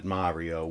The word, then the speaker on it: Mario: My wife is Italian as well, and she pronounces it Mario, which is the right way to pronounce it. But most of us say Mario.